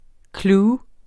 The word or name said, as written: clue